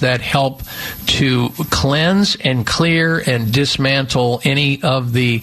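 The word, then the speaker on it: Xlear: So clearing out the sinuses with this all-natural, non-addictive, safe and effective nasal spray, and Clear is spelled with an X, X-L-E-A-R, Clear Rescue Nasal Spray.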